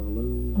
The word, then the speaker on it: loam: Have you heard of loam? – Have I heard of what? – Loam. L-O-A-M. – No. – Pronounce either loam or loom.